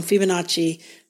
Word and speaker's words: Fibonacci: I said it wrong. Fibonacci. I thought it had an R in it.